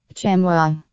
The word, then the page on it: chamois: American English